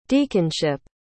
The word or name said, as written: deaconship